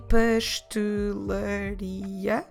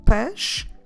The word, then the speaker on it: pastelaria: So I'm not saying pastelaria, I'm saying pastelaria. So we have the word has five syllables, pastelaria, but when we pronounce it normally, we reduce that into just three.